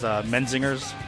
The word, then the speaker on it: Menzingers: I just, from what other people say, is Menzingers.